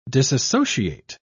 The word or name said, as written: disassociate